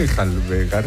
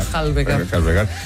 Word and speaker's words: enjalbegar: Para que volviera a enjabelgar, ¿cómo se dice? Enjalbegar la... – Enjalbegar la pared.